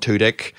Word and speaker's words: Tudyk: But like, Will Sasso was in the episode that I was watching today, along with Alan Tudyk. And is that how you pronounce that man's name? – Yeah. – Okay.